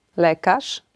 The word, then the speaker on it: lekarz: Zeszyt, lekarz, bagaż. Zwróćcie proszę uwagę na to, że jeśli litera Z z kropką albo RZ znajduje się na końcu wyrazu przed pauzą, wymawiamy ją jako sz.